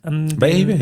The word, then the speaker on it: Bayahibe: Wir sind, und jetzt möge man mich, ich mag es falsch aussprechen, wie auch immer, nach Bayahibe. – Bayahibe, ja.